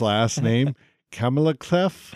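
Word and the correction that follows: Klementieff: Clementif, maybe? – There you go. – Palm Clementif.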